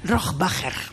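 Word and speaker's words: Rohrwacher: Bueno, ¿cómo se pronuncia Alba Rohrbacher Kochet? – Rohrbacher.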